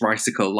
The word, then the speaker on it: Ricicle: So yes, it's Ricicle, like bicycle.